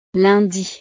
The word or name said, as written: lundi